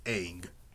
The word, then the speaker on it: Aang: Ong. – Ong. – What? – I hate the main character. The main character's name is Aang. – Aang. – Yeah. They called him Ong.